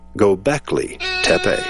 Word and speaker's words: Göbekli Tepe: And if you want to Google Göbekli Tepe, it's spelled G-O-B-E-K-L-I. Second word, T-E-P-E. Göbekli Tepe.